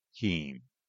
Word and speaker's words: Kein: Timothy J. Keene, and that is spelled K-E-I-N, Gibson City.